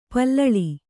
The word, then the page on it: ಪಲ್ಲಿಲಿ: pallili